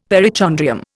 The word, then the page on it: perichondrium: Indian